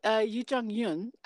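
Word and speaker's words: Youn Yuh-jung: Yoon Jung Yao? – Close. Yoo Jung Yoon. I'm not as probably well versed as saying her name as I should be, but yeah, Yoo Jung Yoon.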